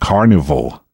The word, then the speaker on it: Karnivool: Next, let's listen to something by the Australian heavy prog band Carnival. That's spelled K-A-R-N-I-V-O-O-L.